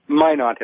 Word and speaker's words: Minot: Minot, as in why. – Minot, as in why not?